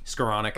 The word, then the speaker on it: Skowronek: And when uh, I can't even say his name, Skronik Skowronek. Skowronek, there you go.